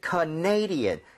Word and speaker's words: Canadian: 왜냐면 캐나다는 캐나다 이렇게 가는데 네. – 이게 캐나다 사람 이러면은 Canadian 강세가 뒤로 하나 밀려요.